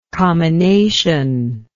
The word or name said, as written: comminative